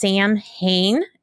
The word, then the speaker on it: Samhain: And Samhain, by the way, it looks like it's pronounced Samhain. So if you see it in writing, you're going to read it as Samhain, but it's a Gaelic word, so it doesn't sound much like it looks. But that's how you say it, is Samhain.